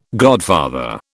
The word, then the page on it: godfather: British English